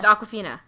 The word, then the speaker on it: Awkwafina: Awkwafina. Oh, yeah. It's Awkwafina. – Yeah. – Oh, no, that's her name in the thing. No, is that her real name? – That's her real name. – Oh, Awkwafina.